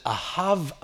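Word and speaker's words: Ahav (אהב): Es wird Ahav ausgesprochen.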